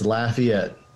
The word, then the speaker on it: Lafayette: No, you'd think you'd put a little French accent on it, but it's Lafayette.